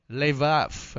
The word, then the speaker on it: levav: In the Old Testament, the word heart comes from the Hebrew word lep or lebap. That's how you pronounce it? Levav. Okay. Lev or Levav.